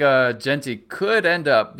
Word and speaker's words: Jeanty: Ashton Jeanty did I say that right? – Boise State. – Jeanty. You're right. Jeanty. Uh, I knew it didn't sound right.